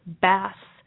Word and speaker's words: Bass: Lance's last name is pronounced like the name of the fish. Bass. Bass. – Okay. – Instead of bass.